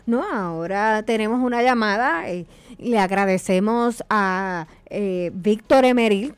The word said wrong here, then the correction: Emeric: que está en línea. Emeric, muchas gracias. Me faltó esa pronunciación, así que gracias, Gole.